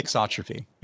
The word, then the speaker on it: thixotropy: Thixotropy. Is that how you say it? – Yeah, okay. Thixotropy.